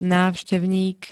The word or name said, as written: návštevník